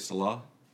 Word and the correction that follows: Selah: Selah. Yeah, Selah. Selah.